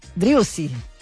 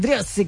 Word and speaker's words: Driussi: Acá me cargaron los chicos porque dije mal el apellido de uno de los que ha metido el tanto. 3-2 River. Driussi. Ahora lo dije bien. Driussi convirtió a los 32 minutos para River,